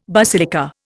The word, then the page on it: basilica: Indian